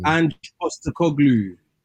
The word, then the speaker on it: Ange Postecoglou: Ange Postecoglou.